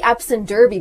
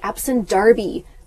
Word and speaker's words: Derby: Britain, they pronounce it not the Epsom Derby, but the Epsom Darby.